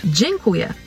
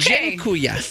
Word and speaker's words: Dziękuję: Every word's going to have like a C-Z-I. – Okay, for your next word, it means thank you, and it's pronounced... – Dziękuję. – What? – Oh, okay. – Dziękuję. – Dziękuję. – G-I-N.